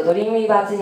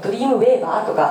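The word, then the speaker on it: Dreamweaver: これを知っているとドリームウィーバーズになれるその 2 というトリビアといたしまして、なんとドリームウィーバーはですね、アドビ製品で、唯一製品名の読み方についてテクニカルノートがリリースされております。というのも、皆さんいきなりドリームウィーバーというふうにですね、英語で見るとドリームウェーバーとかなんて読むのと多分思われると思います。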